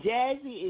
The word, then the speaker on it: Jazzii: And Jazzy is spelled J-A-Z-Z-I-I.